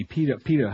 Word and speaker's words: PETA: The PETA people, P-E-T-A. The PETA, how do we say it? – PETA. – Do we say PETA? – PETA. – Oh, okay, thanks.